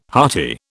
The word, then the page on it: hearty: British English